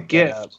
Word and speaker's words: GIF: I'm posting a GIF to the. Also, it's pronounced GIF, not JIF.